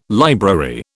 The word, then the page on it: library: British English